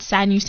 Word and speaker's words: Sanyu: Okay, so you can either type my name out, Sanyu Sematimba, so double S-A-N-Y-U, S-E-M-A-T-I-M-B-A, but my Instagram name is Just Another African, but you don't spell it like how you say it.